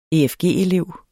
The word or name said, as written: efg-elev